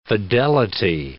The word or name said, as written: fidelity